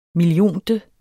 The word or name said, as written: millionte